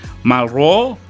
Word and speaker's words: Malraux: André Malro, que se escribe Malraux, que dice, la muerte solo tiene importancia en la medida que nos hace reflexionar sobre el valor de la vida.